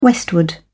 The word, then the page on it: westward: British English